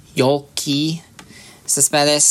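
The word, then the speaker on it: Yoelqui Céspedes: There's a piece of audio I found. It's Yolky Cespedes.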